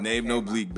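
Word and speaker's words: Nave Noblique: Nave No Bleak Bandcamp.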